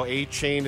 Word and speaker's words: Achane: Kevin Harlan describing what ended up being the 70 point outburst, and now Achane is now A Chan. Right, I believe there's been a pronunciation.